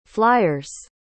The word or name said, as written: flier's